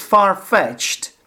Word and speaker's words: far-fetched: Far-fetched. The stress is on the second word. And there is again a hyphen. So far, not near, yeah, far, hyphen, fetched, far-fetched.